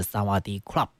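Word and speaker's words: 萨 瓦 迪 club (สวัสดีครับ): 但其实呢，这个泰语似乎有这个阴性阳性的这个区别，女生讲你好是萨瓦迪卡啊，但是男生呢这个发音要是萨瓦迪 club 我也只会这一句而已了。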